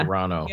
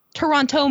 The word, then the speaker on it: Toronto: Toronto Man? – That's right, with the T's? What? – They made a whole movie called Toronto Man, and the actors said Toronto. Toronto. That's not how you pronounce it.